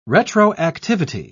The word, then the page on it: retroactivity: British English